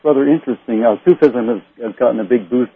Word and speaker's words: Sufism: Well, how do you pronounce that, S-U-F-I-S-M? – Sufism.